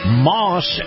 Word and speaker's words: Maus: Yeah, buddy, in this portion of the program, my conversation with Chris Markowski, brought to you by our friends at Moss Acura. Now, Moss is spelled M-A-U-S, and if you feel the love of a high-end SUV, you're going to love Moss Acura.